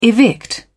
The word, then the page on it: evict: British English